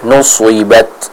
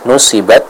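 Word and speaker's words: nuswibat (نُصِبَتْ): Contohnya kita baca nuswibat, nuswibat, ya, jadi bukan nusi. Kalau kita baca dengan tipis nusibat, nanti jatuhnya akan seperti huruf sin.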